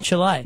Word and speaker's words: Chile: How come you're saying Chile now? – Because you've told me that's how it's pronounced. – The other day you're saying Chile. – Because you've told me it's pronounced Chile.